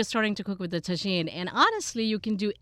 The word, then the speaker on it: tagine: I'm looking for recipes that are less sweet and more savory, and can we cook Indian food in the tagine? Is that how you say it? – Yeah, tagine.